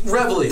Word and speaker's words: Reveille: Is it Reveille? I just want to make sure I say it right. It's like Reveille. – That's right. We're gonna call it right. – Yeah, it's Reveille.